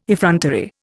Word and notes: effrontery: Indian

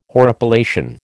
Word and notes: horripilation: American English

